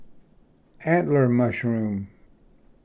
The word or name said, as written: antler mushroom